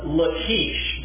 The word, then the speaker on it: Lachish: Lachish, James had pronounced it properly, as I'm told by Hebrew speakers when we've been in Israel these times.